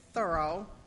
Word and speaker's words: Thoreau: Our reading is by Henry David Thoreau, or I'm told to say it properly, you must say Thoreau.